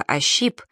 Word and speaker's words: ощип: Попасть как кур во щи, тем более что слово «ощип» всегда произносилось в русском языке с ударением на второй слог.